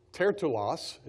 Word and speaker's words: Tertullus: You've got Tertullus, is how you actually pronounce it, the name.